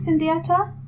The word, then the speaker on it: Sundiata: I mean, I want to know what is it, Sundiata? Mm. How do you spell it? – S-U-N-D-I-A-T-A.